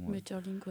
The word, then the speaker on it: Maeterlinck: L'intruse aussi qu'on avait l'année dernière, qui est une mise en scène donc de la pièce de Maeterlinck. Je ne sais pas si je le propose bien, si je le prononce bien, pardon. – Maeterlinck, ouais.